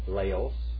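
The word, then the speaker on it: Laos (λαός): Laos.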